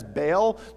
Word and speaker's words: Baal: Some of you maybe read that as Baal. The proper pronunciation is Baal.